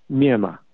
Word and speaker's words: Myanmar: Myanmar.